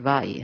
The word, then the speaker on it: Vai: I think Vi when I look at it. – I do too, but then I heard one little documentary on YouTube and they said Vai, and I thought, it's got syllables. Okay.